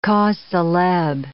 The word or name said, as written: cause celebre